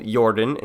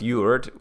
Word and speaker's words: Jord: The planet Earth is called Jorden in Swedish. It literally means the Earth, roughly pronounced Jord, with a silent R and a thick D like in American English.